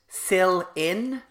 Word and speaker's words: Celine: Although what I did notice about her, and Stacey at some point during it, they pronounce it Sil-in. – Yeah, Celine Dion, as opposed to Celine Dion.